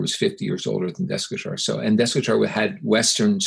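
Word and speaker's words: Desikachar: And my initial meeting with Deskachar, or Deshikachar is probably how you correctly pronounce it,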